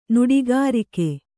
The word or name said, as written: ನುಡಿಗಾರಿಕೆÉ